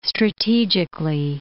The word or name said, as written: strategically